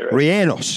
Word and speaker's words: rellenos: We've made chile rellenos together. – No. That's cool. – No, you can't even say it right. Rellenos.